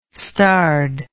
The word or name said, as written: starred